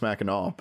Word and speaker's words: Mackinac: It's spelled Mackinac. It's pronounced Mackinac.